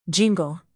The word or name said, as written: jingle